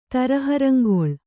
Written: ತರಹರಂಗೊಳ್

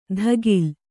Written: ಧಗಿಲ್

